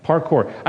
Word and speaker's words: parkour: Parkour, is that what it is? – Yes. – Yes. Did I say it right? Parkour.